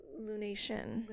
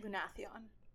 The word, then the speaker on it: Lunathion: Lunation." – Lunathion.